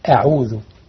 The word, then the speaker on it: أعوذ: كلمة أعوذ نص على ابن الجزر وقال يعني ورققا مستفلا من أحرفي، وحاذرا تفخيم لفظ الألف كهمز الحمد، الحمد أعوذ اهدنا الله، يعني ما تفخمش الهمزة أعوذ مش لأن الهمزة ما خرجها من أفصح الحلق.